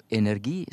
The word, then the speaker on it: energi: Eh, der vil jeg si som tidligere språkkonsulent i NRK at eh, den tradisjonelle uttalen vil nok mange si energi. Jeg ville tilrå energi, siden det er 100 korrekt og faktisk mest i samsvar med opphavet, og det er også det som gir det mest funksjonelle i norsk, nemlig samsvar mellom tale og skriftbilde.